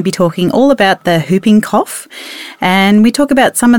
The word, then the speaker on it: whooping cough: The W is silent, so it's whooping cough, everybody.